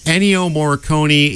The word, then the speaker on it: Ennio Morricone: Ennio Morricone? – Ennio Morricone is a mortal lock to win this award.